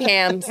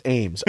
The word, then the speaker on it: Ames: I just bring hams. – That's what she I got mean. Hams, girl. – Yeah. – Yo, you know Lindsay Hams? It's pronounced Ames.